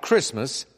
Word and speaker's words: Christmas: White Christmas has 3 silent T words. Christmas, listen, and this verb that follows the treetops.